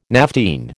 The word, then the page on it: naphthene: American English